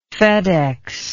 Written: fedex

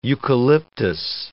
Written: eucalyptus

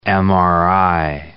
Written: MRI

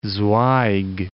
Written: Zweig

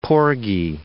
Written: porgy

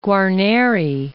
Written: Guarneri

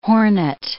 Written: hornet

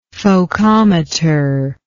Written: focometer

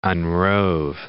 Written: unrove

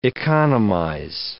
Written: economize

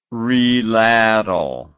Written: reladle

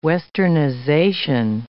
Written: westernization

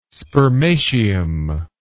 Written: spermatium

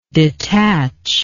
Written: detacher

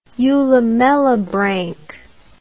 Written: eulamellibranch

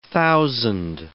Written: thousand

